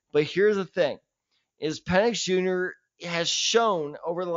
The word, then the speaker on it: Penix: They should draft at number 9, Michael Penix Jr., the quarterback out of Washington.